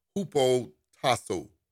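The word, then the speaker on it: hupotasso: The word submission in Greek is, amen, is pronounced H-U-P-O, hupo tasso, T-A-S-S-O, H-U-P-O-T-A. S-S-O hyphen above.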